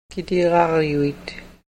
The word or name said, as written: kitigaaryuk